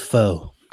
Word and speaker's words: pho: It's pronounced pho.